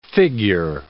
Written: figure

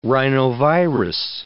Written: rhinovirus

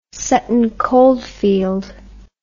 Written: Sutton Coldfield

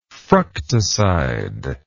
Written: fructoside

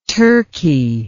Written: Turki

